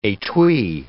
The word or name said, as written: etui